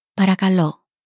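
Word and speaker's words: Parakalo (παρακαλώ): Parakalo.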